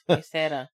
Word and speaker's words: Huesera: It's Huesera. – Yeah, that's what I said. – You said Huesera. – Huesera. That's what I said. I just didn't roll my R.